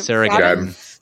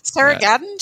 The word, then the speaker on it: Gadon: Sarah Gadon. – Sarah Gadon, she is luminous in that.